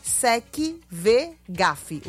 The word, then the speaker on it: SecVegaf: É @secvegaf. O V é mudo, tá? SecVegaf.